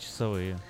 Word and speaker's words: часовые: Кстати, часовые или часовые? – 12 часовые.